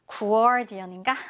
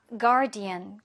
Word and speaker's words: guardian: 얘가 이제 뭐 유가 있어서 발음을 할때 guardian인가? 이렇게 생각할 수 있는데, 그냥 유는 없다고 생각하고, 그냥 아 발음으로 하면 돼요. 그래서 조금 길게 끌면 됩니다. guardian.